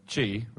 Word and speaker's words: qi: Is chi, which is spelled QI.